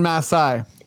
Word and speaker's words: Masai: Trust in Masai. We're actually the ones who pronounce it right.